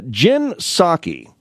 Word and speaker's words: Psaki: But Jen Psaki, P-S-A-K-Y, I believe the P is silent.